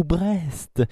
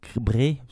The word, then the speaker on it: Brest: ou Brest, ou Bré, ça dépend comment on prononce.